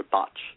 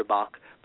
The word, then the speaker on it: Bach: it's Dr. Botch. It looks like it should be Dr. Bach, but it's B-A-C-H, but people pronounce it as Botch in England.